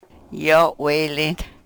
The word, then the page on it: yo we-lin: Yurok